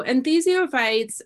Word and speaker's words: enthesiophytes: Enthesiophytes. – Thank you. – Yeah. – The lateral and medial side of the navicular bone is seen in very upright pasterns. – That could be. So enthesiophytes are basically bony changes at the site of where tendons and ligaments attach.